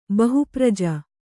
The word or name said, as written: ಬಹುಪ್ರಜ